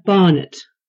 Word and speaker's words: Barnett: Carolina Barnett. – Barnett? How do you spell that? – B-A-R-N-E-T-T.